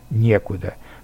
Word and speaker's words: некуда: И, наконец, к безличным предложениям относятся предложения с отрицательными местоимениями и наречиями, как «некого», «нечего», «не о чем», «некогда», «некуда», с ударением на первом слоге.